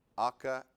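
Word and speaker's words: aka (ἀκοή): That word there, when it says hearing by faith, it's pronounced aka, aka, aka. Sounds like a rap.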